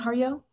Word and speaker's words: Harjo: I really, is that how you pronounce her name? Harjo? – I've been told Harjo. I've been pronouncing it Harjo.